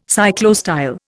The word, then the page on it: cyclostyle: Indian